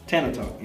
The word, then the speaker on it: Tana Talk: At number 6, I have Benny the Butcher, Tana Talk. I said that right? – Tana Talk, yeah.